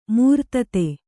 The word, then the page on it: ಮೂರ್ತತೆ: kannada